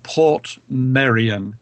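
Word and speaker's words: Portmeirion: On the north coast of Wales, there is a wonderful place called Port Merion. Merion, by the way, is spelled M-E-I-R-I-O-N.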